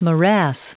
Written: morass

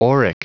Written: auric

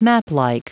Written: maplike

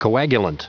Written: coagulant

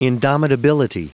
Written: indomitability

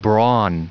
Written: brawn

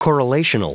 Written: correlational

